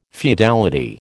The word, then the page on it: feudality: American English